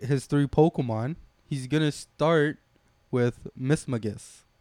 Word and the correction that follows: Mismagius: Mismagius, I think.